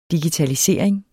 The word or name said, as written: digitalisering